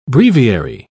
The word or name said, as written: breviary